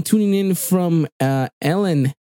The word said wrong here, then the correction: Elgin: Say it, but I don't think you said that correctly. – I'll say Elgin. – Elgin, there you go. Elgin, Texas, probably.